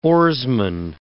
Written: oarsman

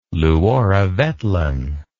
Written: luoravetlan

